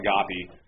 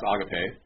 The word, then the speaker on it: agape: They always call it agape. It's pronounced agape.